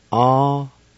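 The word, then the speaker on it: awinutsa: And it's pronounced like this. A-wee-nu-za. A-wee-nu-za.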